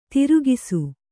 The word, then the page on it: ತಿರುಗಿಸು: tirugisu